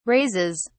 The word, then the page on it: raises: British English